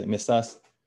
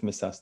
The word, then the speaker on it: Messas: There's different ways to pronounce his name. Some say Rav Yosef Meshach, some say Meshach, some say Messas. I'm not sure myself, but we'll go with Rav Yosef Messas today.